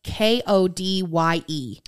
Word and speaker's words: Kodye: So her Instagram is uh, Kodye, but it's spelled K O D Y E. Okay. So it's Kodye Elise.